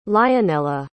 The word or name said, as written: lionella